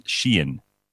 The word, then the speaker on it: Shein: Although Bazitsana says it's pronounced Sheen.